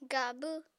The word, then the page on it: gabw: apatani